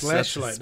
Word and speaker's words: Fleshlight: Yes, Fleshlight, not Fleshlight. – Yeah. Flesh. – Flesh. – It's flesh. – Yeah, you were pronouncing it like flush.